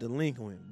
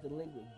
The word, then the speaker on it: DLEQUINT: And before you go, my dog, I need you to look into your camera and say, it's pronounced delinquent, bitch. – Where? We are here. – Damn. It's pronounced delinquent, bitch.